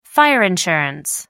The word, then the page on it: fire insurance: American English